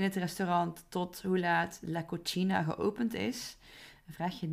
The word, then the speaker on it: cocina: En wat ik ook vaak voorbij hoor komen is in plaats van cocina, la cocina, de keuken, dat er wordt gezegd la cocina. Alsof de C op zijn Italiaans wordt uitgesproken.